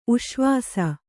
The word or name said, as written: ಉಶ್ವಾಸ